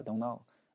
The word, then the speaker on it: don't: OK，第十个比较简单啊，就 I don't know，这个 I don't know what to do，因为这个 don't 这个 t 不发音嘛。正常人自然而然的直接变成这个 don't know， I don't know what to do，我不知道该干嘛。